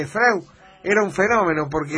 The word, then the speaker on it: Freud: El diptongo EU en alemán, y el idioma madre, es hoy, Freud. Por eso Freud. – Litongo. – El diptongo EU de Freud, como dice usted, es, EU. – Se pronuncia hoy.